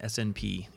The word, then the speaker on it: SNP: It's an acronym, SNP. You pronounce it SNP.